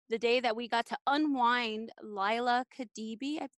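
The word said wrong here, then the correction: Kebede: Leah Khadibi, is it? – It's Leah Khabede.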